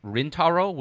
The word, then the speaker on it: Rintaro: This includes Masao Maruyama, Osamu Dezaki, Rintaro, which is a pseudonym for Shigeyuki Hayashi, and also Yoshiaki Kawajiri.